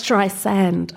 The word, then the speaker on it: Streisand: It's Streisand. I don't even know if I'm saying it right. – Yo, it's with a soft S, like sand, not Zand."